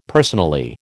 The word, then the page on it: personally: American English